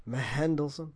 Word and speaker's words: Mendelsohn: There's like an H, silent H in there. It's insane. – Yeah, I see. – Mendelsohn.